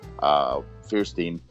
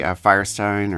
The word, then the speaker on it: Fierstein: The thing Cyndi Lauper wrote, all the words, all the music, and, you know, Harvey Fierstein. How do you say his name? – I always say Firestein or Fierstein. Either Fierstein. – Way. I think it's okay. It's either or.